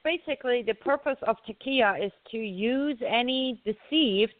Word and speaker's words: taqiyya: How do you say it? – Yes, taqiyya.